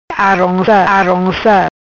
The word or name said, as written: Aronca Aronca